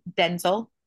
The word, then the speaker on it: Denzel: So it would be Denzel, and I say his name that way because there's an interview where he tells a TV host, he's like, actually, that's not how you say my name. My name is technically Denzel because my dad's name is Denzel and I was named after him, but my mom got tired of neither of us responding.